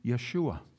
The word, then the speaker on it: Yeshua: Actually, his name originally, if we had pronounced it back then, would have been Yeshua, which is not unlike Yahweh.